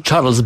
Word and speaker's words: Charles: Charles, tak po francusku? Birma, mam na myśli kardynała Charles, tak? – Charles Bon, bo Charles, tam się mówi po angielsku, więc to jest...